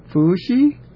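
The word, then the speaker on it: Fuxi: Oh, it's Fu Shi? Okay. Yeah. Fu Shi. That's the pronunciation.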